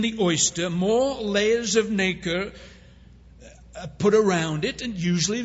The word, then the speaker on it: nacre: When a foreign object invades an oyster, the animal coats the irritant with a substance called nacre. It's acres with an N in front of it. That's how you spell it.